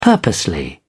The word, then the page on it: purposely: British English